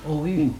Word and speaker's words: août: Si vous deviez transcrire phonétiquement ce mot, rien de plus simple, vous écriez simplement « ou ». – « Ou ». Et « ou ». Alors, toutes les autres prononciations sont des prononciations dialectales.